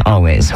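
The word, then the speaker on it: Alvvays: It's spelled with two V's, but it is still pronounced Always.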